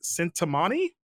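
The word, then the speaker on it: Cintamani: Namely, the, what was it, Sintamani stone? I guess how you pronounce it. – Sounds right.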